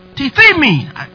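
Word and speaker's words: tithemi (τίθημι): And we have the verb tithemi, which is tithemi. I should say the accent is on the second syllable.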